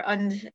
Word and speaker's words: Ende: Michael Ende, or Und, as it would be pronounced in German.